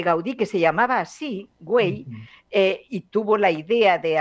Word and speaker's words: Güell: Porque yo se titula Parque Güell. Porque Güell, Güell, no es que sea inglés ni nada, es un apellido de aquí, de Cataluña.